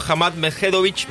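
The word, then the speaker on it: Hamad Medjedovic: Mariano Navone va a estar jugando ante el serbio Hamad Medvedovic mañana, tercer turno.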